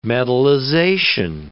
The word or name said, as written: metallization